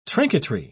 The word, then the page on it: trinketry: British English